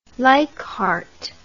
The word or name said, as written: Leichhardt